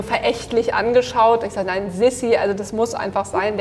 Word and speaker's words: Sisi: Und ich würde gern mit Sissy anfangen oder wie wir gerade besprochen haben, Sissy ja, Sisi, äh, schreibt, Sisi.